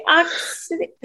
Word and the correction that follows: Ixodes: I don't know how to say it. You say it. – No, try it one more time without all us laughing. – No, I'm not doing it again. Tell me the correct way to pronounce. – I think it might just be your Georgia accent. – I'm not saying it again. Let me hear you say it. – Ixodes. – Ixodes, thank you.